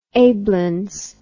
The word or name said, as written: ablins